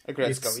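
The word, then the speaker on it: Aggretsuko: Okay, so I can say that firstly, I watched Agretsuko, or however it's pronounced. – Agretsuko, yeah. – What? – Agretsuko, yeah. – Agretsuko, okay.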